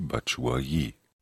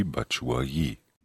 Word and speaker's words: Batshuayi: Michi Batshuayi. – Oh, die hat aber eine schöne, tiefe Stimme. Können wir das nochmal hören, bitte? – Michi Batshuayi. – Michi Batshuayi.